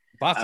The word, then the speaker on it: Pato: Pato, that's how you say it. Okay. – Pato. – Pato. Okay. – Not Pato. I don't want anybody on Twitter going off on us for saying the names after 5 years.